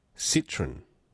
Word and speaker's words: Citroën: Citroën is a French car pronounced Citroën. Citroën. Citroën? Not Citroën. It's not a citrus fruit. It's a car.